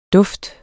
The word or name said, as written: duft